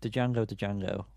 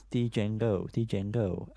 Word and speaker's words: Django Django: So Django Django, which I know is not how you pronounce it, by the way. It's Django Django.